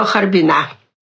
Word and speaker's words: Харбина: Ну, а потом, значит, дальше станция Маньчжурия, и, в общем, уже добрались до Харбина. – Кстати, вот это ударение на последний слог «Харбина» в «Харбине» — это типично для наших героев, они все так говорят.